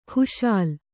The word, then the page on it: ಖುಶಾಲ್: khuśal